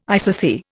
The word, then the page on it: isothere: Indian